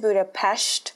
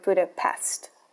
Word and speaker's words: Budapest: She says she knows that people have actually been to Budapest if they say, oh, I've been to Budapest instead of Budapest. So the more you know, you guys can speak to any Hungarians you might know and share that bit of knowledge and also sound really cultured pronouncing the city in the actual way that it's pronounced in their native language.